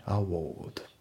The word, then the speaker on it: award: であとその発音なんですけどそのアワード、ね、そのアワードって日本語とかでね言うしその綴りを見たらねアワードっていうふうに言いたくなると思うんだけどもその発音はアワード、ね、アワードですね。